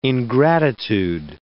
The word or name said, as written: ingratitude